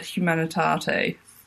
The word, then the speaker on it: humanitate: I had to listen to the pronunciation on Google, and it said humanitate.